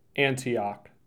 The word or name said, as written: Antioch